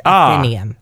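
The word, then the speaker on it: Athenaeum: Because it's Athena with a U-M at the end. Athenium.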